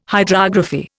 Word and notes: hydrography: Indian

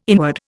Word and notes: inward: Indian

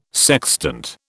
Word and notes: sextant: British English

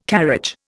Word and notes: carriage: Indian

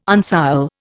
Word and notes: uncial: Indian

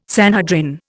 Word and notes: Sanhedrin: Indian

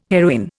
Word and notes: heroine: Indian

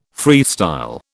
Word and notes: freestyle: British English

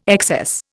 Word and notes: excess: Indian

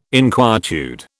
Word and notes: inquietude: British English

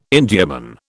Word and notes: Indiaman: British English

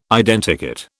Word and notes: Identikit: British English

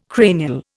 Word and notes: cranial: Indian

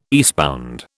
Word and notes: eastbound: British English

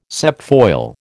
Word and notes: septfoil: American English

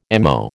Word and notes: MO: American English

